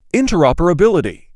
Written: interoperability

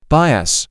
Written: Bias

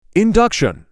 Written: Induction

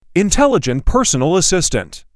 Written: Intelligent personal assistant

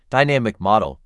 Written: Dynamic model

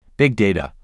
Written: Big data

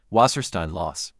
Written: Wasserstein loss

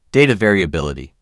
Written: Data variability

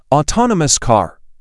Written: autonomous car

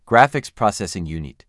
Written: graphics processing unit

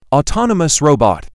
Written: autonomous robot

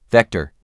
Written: Vector

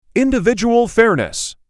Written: Individual fairness

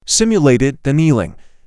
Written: Simulated annealing

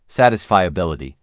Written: Satisfiability

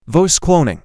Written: Voice cloning

